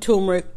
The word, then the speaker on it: turmeric: So it's got natural, the color is from turmeric, because everybody forgets there's an R before the M, turmeric.